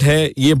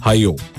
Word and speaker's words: Hayyoh: एक नई ऐप जो इंट्रोड्यूस गई है जिसका नाम है हायो platform, HAYYOH, I hope I am pronouncing it right, हाया था तो ये हायो होगा, हायो प्लेटफॉर्म, और इस ऐप के जरिए आप पॉइंट्स अर्न कर सकते हैं।